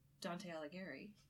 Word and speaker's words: Dante Alighieri: So, what I thought was interesting, our next book is The Inferno by Dante Alighieri.